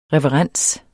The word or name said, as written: reverens